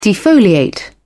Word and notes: defoliate: British English

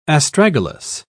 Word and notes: astragalus: British English

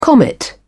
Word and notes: comet: British English

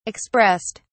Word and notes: expressed: British English

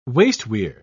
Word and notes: wasteweir: British English

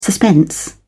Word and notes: suspense: British English

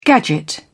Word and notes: gadget: British English

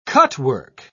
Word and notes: cutwork: British English

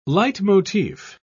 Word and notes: leitmotif: British English

